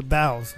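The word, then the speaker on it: bao: Oh, the bows. Is that bows, pronounced bow? Yeah. I was, because the S is on there, it threw me off.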